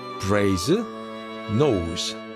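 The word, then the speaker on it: Brasenose: 철자법 그대로 발음하시면 됩니다. 브레이즈 노스.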